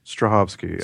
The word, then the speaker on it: Strahovski: No, she may have had work since, but how do you say her name? Yvonne what? – Strahovski. Strahovski.